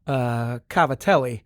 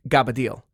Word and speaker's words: cavatelli: It's a type of pasta that is spelled, and I believed pronounced, cavatelli, but we always, because of our garbage Italian roots, that meal was broccoli and gabbadile. That's what we always said, a word that I have not encountered elsewhere in the world.